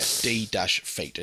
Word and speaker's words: D-Feet: And just to clarify for those people listening, it's spelled D, the letter D, dash, feet. It's not the word defeat. – Yes, correct. Yeah, D-feet.